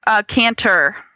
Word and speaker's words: Kantor: Kantor.